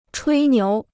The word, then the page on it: 吹牛: chuī niú